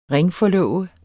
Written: ringforlove

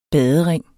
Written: badering

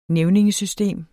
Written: nævningesystem

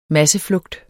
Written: masseflugt

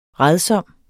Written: rædsom

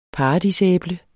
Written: paradisæble

